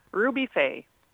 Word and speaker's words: Ruby Faye: Y si lo ves escrito, quizás un poco más difícil porque FAY se escribe F-A-Y-E. Entonces, si no sabes pronunciarlo en inglés, hmm.